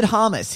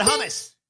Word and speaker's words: hummus: He said hummus. He just got the stress wrong. The sounds were correct.